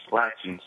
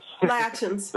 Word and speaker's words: Appalachians: And is this an accurate recreation of the, and please excuse me if I pronounce it wrong, the Appalachians of North Carolina? – Latchins. – Latchins. – The Appalachians. There Appalachians. – Yeah.